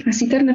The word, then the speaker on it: Nasiterna: Koło naukowe filologów klasycznych Nasiterna. Czy ja dobrze wymawiam? – Jak najbardziej. Nasiterna, czyli konewka po łacinie.